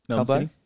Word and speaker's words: Talbot: Talbot? – I like to pronounce the second T. – Yeah, fair enough. – Hard T.